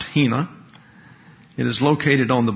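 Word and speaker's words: hina (ἵνα): It's pronounced hina, and it's translated into the English by the word that.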